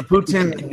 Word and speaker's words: poutine: Is it poutine? Is that how you say it? – Poutine. – Poutine. Poutine. Poutine. A poutine burger. – Oh, we get think of the Tin Man. It's poutine.